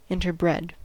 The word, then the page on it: interbred: American English